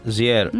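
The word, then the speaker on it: JR: Πώς τον προφέρεις τον καλλιτέχνη? Ζιέρ. Ναι. Ο Ζιέρ, θυμάσαι ότι είχε σκηνοθετήσει μαζί με την Αγιές Βαρντά το Πρόσωπα και Ιστορίες, ένα πολύ ωραίο το 2017 βραβευμένο ντοκιμαντέρ δρόμου.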